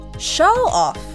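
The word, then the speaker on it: show-off: 名詞として発音する場合は show off 目立ちたがりという意味です彼ってほんと目立ちたがりよね。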